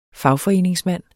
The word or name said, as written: fagforeningsmand